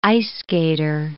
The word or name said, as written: ice-skater